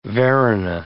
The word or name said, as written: Varuna